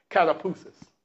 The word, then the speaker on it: katapausis: The Greek word for rest is kataphusis. It's spelled K-A-T-A-P-A-U-S-I-S, kataphusis.